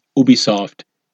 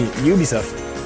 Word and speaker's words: Ubisoft: Today, we will talk about Ubisoft Entertainment Société Anonyme from France.